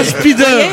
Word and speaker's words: Spider: Ah oui, Spider. – Là, on repasse au i qu'on prononce aïe.